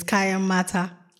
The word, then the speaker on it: Kayan Mata: No, it's Kayamata.